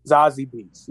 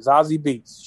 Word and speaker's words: Zazie Beetz: He uh, he's a, yeah, he he was in a movie with um ZZ Beetz, ZZ Beetz. I don't know how to say her name. Zazie. Zazie Beetz. Yeah, Zazie Beetz.